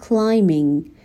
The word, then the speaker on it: climbing: And climbing بی تلفظ نمیشه. نمیگم climbing. البته شما گفتین من اشتباهات رو نگم. ولی من میگم که بیشتر بهشون آگاه بشین. ما بی رو نمیخونیم. میگیم climbing. Climbing menacing hills.